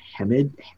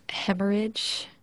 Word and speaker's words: hemorrhage: You see also that there is a subdural hemorrhage. – I believe it's pronounced hemorrhage.